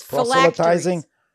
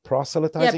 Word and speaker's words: proselytizing: It's proselytizing. – Proselytizing. – Yeah, proselytizing.